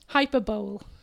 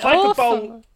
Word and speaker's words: hyperbole: I did an entire presentation about hyperbole, and I pronounced it hyperbole. – Yes! Oh, my God. – It's hyperbole.